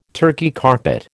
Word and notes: Turkey carpet: American English

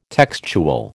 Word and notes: textual: American English

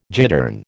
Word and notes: gittern: American English

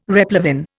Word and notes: replevin: Indian

